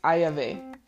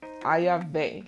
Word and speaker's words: Ayave: It's Ayave. Ayave. Like Fay, but with Vay.